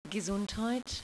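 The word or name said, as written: Gesundheit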